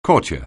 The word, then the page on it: courtier: anglais